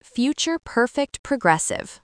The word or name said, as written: future perfect progressive